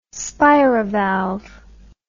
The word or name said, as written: spirivalve